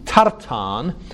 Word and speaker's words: Tartan: Now, in Hebrew, Tartan is spelt with a short A, Tar, and then a long A, Tan.